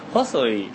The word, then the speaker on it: Hosoi: Bueno, si se pronuncia bien sería como Hosoi, que es una cosa como... Claro, como Hosoi. Claro, complicadísimo. – Antes de conocernos, pronuncia la H como J porque somos españoles.